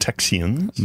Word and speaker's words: Texians: The Texians, the settlers, the white settlers, were called Texians. – Not, you're not mishearing that. – T-E-X-I-A-N-S.